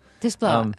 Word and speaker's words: Desplat: Am I saying his name right, by the way? – Yes, that's about right. Okay. Alexandre Desplat. – Desplat.